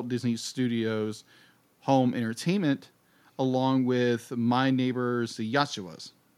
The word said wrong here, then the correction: Yamadas: Am I saying that right? – Was what now? – My Neighbor's the Yamadas. – Yamadas. I knew I was going to say that wrong.